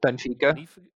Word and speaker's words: Benfica: Benfica.